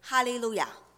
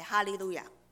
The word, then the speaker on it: Hallelujah: 第一节是宣召的经文，好像刚才一样，第一节是宣召的经文，他说你们要赞美耶和华，原文的发音是 Hallelujah，第一句是 Hallelujah。